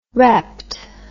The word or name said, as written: repped